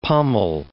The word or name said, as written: pommel